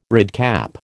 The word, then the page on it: redcap: American English